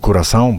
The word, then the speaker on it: Curaçao: Nawet wspólnie śledziliśmy mecz Niemcy Curaçao, bo tak się wymawia nazwę tego kraju, czego się nauczyłem dzisiaj.